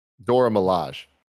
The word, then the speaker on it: Dora Milaje: There will be new adversaries, including two central villains, what Mick Lloyd calls, quote, a robust cast of Wakandans, parentheses, like the Dora Maji. Or I'm not saying that. Is that Maji? Is that how you say it? – Dora Milaje.